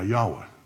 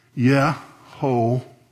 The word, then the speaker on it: Yahweh: So it sounds like Yehovah, but it's actually the word Yahweh.